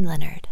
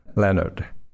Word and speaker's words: Leonard: Because for those people who could not pronounce my name, they say, oh, you are the same as John Lennon. Leonard. Lennon.